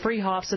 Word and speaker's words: Fruhoffs: Spelled Fruhoffs, pronounced Freehoffs, at the Pyramid at 33rd and Arapahoe in Boulder.